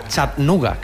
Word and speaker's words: Chattanooga: Vuelo no directo, pero si Dios quiere, por la noche estaremos en Chattanooga, que me dicen que se pronuncia así.